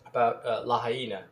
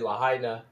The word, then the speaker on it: Lahaina: Lahaina. And I know that people say Lahaina, but as we tried to mention before, I believe the correct pronunciation is Lahaina.